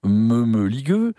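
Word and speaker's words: Mămăligă: Et dans Mamaliga, pour le prononcer à la roumaine, meumeligueux,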